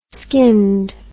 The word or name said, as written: skinned